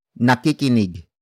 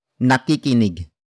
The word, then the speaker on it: nakikinig: Word for word it's nakikinig. Si. Laurel. Sa. radyo Nakikinig. Si. Laurel. Sa. radyo Nakikinig. Si. Laurel. Sa. radio. The first word is nakikinig.